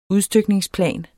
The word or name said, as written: udstykningsplan